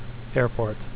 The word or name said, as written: airport